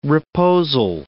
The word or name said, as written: reposal